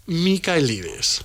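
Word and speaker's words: Michaelides: Pues en principio creo que se pronuncia Alex Mikaelides.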